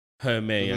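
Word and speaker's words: Hermès: It's like Goyard or Hermes or Hermé, as some people pronounce it, or Versace.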